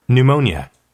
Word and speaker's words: pneumonia: pneumonia, pneumonia. – 폐렴. 폐렴을 pneumonia라고 그래요. 맨 앞에 P는 묵음이 되었어요. 옛날엔 P를 읽었겠죠.